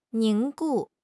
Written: 凝固

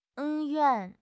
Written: 恩怨

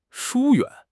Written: 疏远

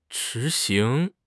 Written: 执行

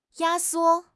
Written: 压缩

